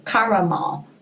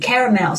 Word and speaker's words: caramel: 这个焦糖呢，就是 caramel，caramel 或 caramel。呃，就是有两种发音，其实都可以。 Caramel 是美式发音，caramel 是那个英式发音。 Caramel macchiato 就是焦糖玛奇朵。